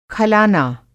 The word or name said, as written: खलाना